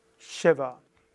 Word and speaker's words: Shiva: Man, manchmal findet man Shiva mit S und manchmal mit SH. Ausgesprochen wird es immer Shiva. Shiva.